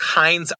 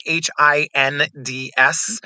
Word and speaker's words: Hinds: So Instagram and TikTok, I'm Patrick Hinds underscore. My last name is spelled weird. It's H I N D S.